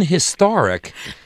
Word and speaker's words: historic: In historic, the H is sounded less, but it is still sounded. And it is still aspirated, but the emphasis is on the second syllable.